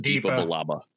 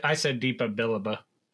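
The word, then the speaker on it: Depa Billaba: How did you pronounce her name? I pronounced it Deepa Balaba. – Yeah, yeah, I said Deepa Balaba.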